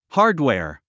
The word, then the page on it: hardware: hάɚdwèɚ; ハードウェア